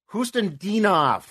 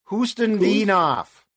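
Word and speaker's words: Khusnutdinov: Hustandinov. – Hustandinov. – Hustandinov. – Kustandinov. So the K Hoost. – Doesn't count. The K Correct. Doesn't count. – Silent.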